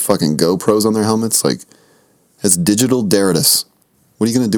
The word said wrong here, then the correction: detritus: How would you say that, Mark? – I think you're looking for the word detritus.